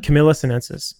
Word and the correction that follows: Camellia sinensis: I'm probably butchering it. Camellia sinensis.